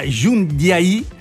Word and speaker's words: Jundiaí: No, volevo intanto salutare Marcelo perché ci sta ascoltando e vive a Jundiaí, con l'accento sulla I.